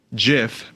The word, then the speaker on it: GIF: In the Oxford Dictionary, it was the word of the year in 2012, and it says GIF may be pronounced with either a soft G as in giant or a hard G as in graphic.